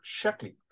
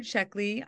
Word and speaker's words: Sheckley: His name was Robert Sheckley, and I hope I got pronounced his name last name correctly. – Yes, absolutely. So Robert Sheckley, my dad, was a science fiction writer and known as a humorist and an absurdist.